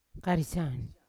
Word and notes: karisya: Yawa

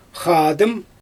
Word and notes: xaadɯm: Xyzyl

